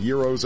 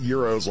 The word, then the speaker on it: gyros: And just so you know, did you hear how I pronounce that? It's a Greek word. It is not pronounced gyros or gyros or gyros. The G is silent in this delicious Greek dish. Google it and see for yourself.